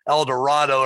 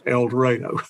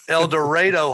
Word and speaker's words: El Dorado: So, well, that's how it's pronounced most places except El Dorado. – El Dorado High School in El Dorado, Arkansas. I need to practice that.